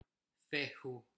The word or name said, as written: fehu